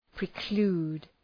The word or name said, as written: preclude